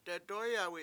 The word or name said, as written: dedOyawi7